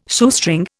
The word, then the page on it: Shoestring: Indian